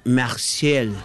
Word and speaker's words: Marseille: Se pronuncia Toulouse, y Marsella se pronuncia Marseille.